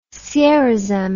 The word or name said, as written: sierozem